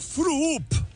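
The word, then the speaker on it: Früüp: Por ejemplo, Froop. Froop se escribe F-R-U-U-P con diéresis en las dos U's.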